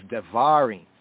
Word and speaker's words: Devarim: In the modern Hebrew, it's enunciated, pronounced as Devarim, right?